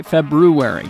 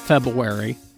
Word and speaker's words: February: February, you got to say it right. It's not February. It's February. There's an R-U in there.